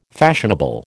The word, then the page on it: fashionable: American English